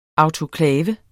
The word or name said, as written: autoklav